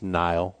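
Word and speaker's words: Knile: Joseph Randall, Lance Dunbar, Boom Heron, Latavius Murray, Kenile Davis. – It's pronounced Nile Davis.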